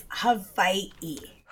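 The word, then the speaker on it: Hawaii (Hawaiʻi): The proper pronunciation. – Here we go. Erin, write that down. Is Hawaii. Hawaii. With like a little apostrophe between the eyes? It's not an apostrophe, it's an okina.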